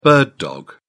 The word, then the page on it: bird dog: British English